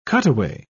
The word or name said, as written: cutaway